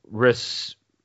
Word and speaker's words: Rhesus: No, Rhesus disease. R-H-E-S-U-S.